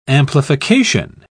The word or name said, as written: amplification